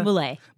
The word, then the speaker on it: Muley: Muley.